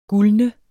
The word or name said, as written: gulne